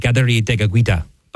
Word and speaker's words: Kateri Tekakwitha: But again, lots to talk about, and a great American saint today, St. Kateri Tekakwitha, or as it would be pronounced by her and her people, it would be St. Kateri Tekakwitha.